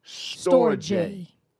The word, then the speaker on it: storge: Number one, the first love is pronounced storge. Storge. – Right. – S-T-O-R-G-E.